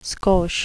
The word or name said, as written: skosh